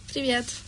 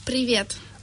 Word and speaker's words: Привет: How do you say hello in Russian? – Привет. – Привет. – Привет? Yeah. – Can you say it again? – Привет. – Ah, привет. Just like previous.